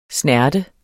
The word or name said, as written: snerte